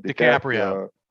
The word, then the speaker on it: DiCaprio: DiCaprio.